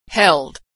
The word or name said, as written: held